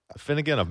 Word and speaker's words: Afinogenov: I'm going to say this name wrong because I used to play against him all the time. But in the Afinogenov? – Afinogenov. Maxim Afinogenov. – Yeah. That's it.